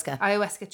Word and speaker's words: Ayahuasca: Ayahuasca trip.